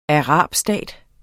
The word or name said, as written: arabstat